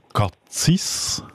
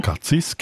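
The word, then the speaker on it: Cazis: Sie ist ursprünglich von Chur, ist aber im Moment in Katzis. Habe ich das richtig gesagt, Frau Karrer? Jawohl, genau. – Katzis, genau.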